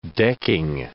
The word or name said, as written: decking